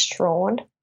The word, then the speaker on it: Strachan: So you can find me, Beck Strawn. I'm on Instagram at Beck, B-E-K, Strawn. Now, my last name is pronounced Strachan. So S-T-R-A-C-H-A-N is how it's spelled, but it's pronounced Strawn, but it's spelled Strachan.